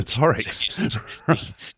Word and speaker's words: combinatorics: but it's combinatorics. Is that how you say it? – Combinatorics, I think, is the pronunciation. Oh, combinatorics.